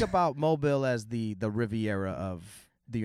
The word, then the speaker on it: Mobile: The only one that has a crazy mispronunciation that has convinced the country is Mobile. That's not how you say that. That word is mobile. – I was mobile. – Yeah, no, it's Mobile, Alabama, because they have like French roots.